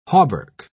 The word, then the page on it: hauberk: British English